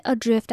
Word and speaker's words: adrift: 然后 adrift，它虽然是 a 开头，可是它念起来是 a 的音 adrift。